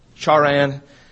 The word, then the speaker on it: Haran: Then came he out of the land of the Chaldeans and dwelt in Haran. The pronunciation is Charan.